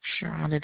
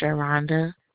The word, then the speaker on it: Sharonda: Sharonda Daniels. – What is it, ma'am? – Sharonda Daniels. – Okay, how do you spell that? – S-H-A-R-O-N-D-A. Daniels, D-A-N-I-E-L-S.